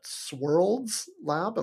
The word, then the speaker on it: Swirlds Lab: You're currently a staff DevOps engineer at Swirlds Lab. Am I saying that right? S-W-I-R-L-D-S? – Yep.